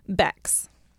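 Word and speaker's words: Becks: C-K-S, cool. – Bex. I know, I like it too. Because it sounds like an X, but it's an S. – Yeah. – You know, Bex.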